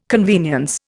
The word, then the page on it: convenience: Indian